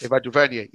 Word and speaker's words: Ava DuVernay: So there was a news that came out recently that Naomi is getting a TV show produced by Ava DuVernay. I'm not sure if I'm pronouncing the name right. – Ava DuVernay, yep.